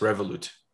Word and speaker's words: Revolut: How that's spelled? That's how that's pronounced? – Yes, Revolut.